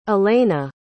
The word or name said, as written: alaina